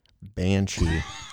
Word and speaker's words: Banshee: I've heard of that one, and I can pronounce it Banshee.